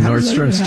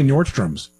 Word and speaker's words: Nordstrom: A lot of people say Nordstrom's. They put an S on there. – What did I say? – You said it correctly. You said Nordstrom. – Okay. – And so did John. But I'll tell you, people, Nordstrom's. It's not Nordstrom's.